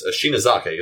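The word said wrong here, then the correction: Sonezaki: isn't it? – Yeah, Rika. – Yeah, Rika. I like the last name. – Oh, Sonazaki. – Sonazaki. My mistake.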